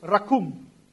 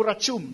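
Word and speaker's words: rakum (רחום): Now, in Hebrew word, it is called rakum, hindi po rachum, dahil sa Hebrew, pagka C-H-cum. Yan ang pagbigkas.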